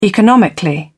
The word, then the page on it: economically: British English